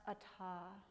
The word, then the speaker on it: atha: And this week, in the classes I've been teaching, I've been sharing the Sanskrit word atha, spelled A-T-H-A, pronounced atha, and it means now.